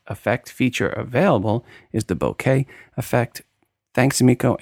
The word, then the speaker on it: bokeh: The word is bokeh, not bokeh, like Phil Schiller and lots of photography people mispronounce it. E is always e in Japanese.